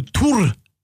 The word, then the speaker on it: Thor: Also er ist quasi Thor oder Tur, wie er im dänischen Original ausgesprochen wird.